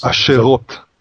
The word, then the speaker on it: Asherot: They forgot Jehovah their God, and they served the Baals, and I can never pronounce this, Asherahs. – Asherot. – Asherot. – Asherot, yeah. – That's the plural.